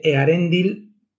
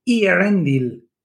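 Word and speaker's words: Eärendil: Lo mismo que yo que sé, Earendil tenía diéresis para que la gente no dijera Earendil.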